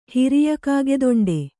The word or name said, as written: ಹಿರಿಯಕಾಗೆದೊಂಡೆ